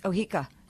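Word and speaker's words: Oheka: Then there was Ohika Castle, which is out in, I think I'm mispronouncing it, but no, you're not. It's Ohika.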